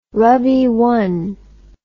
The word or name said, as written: rabii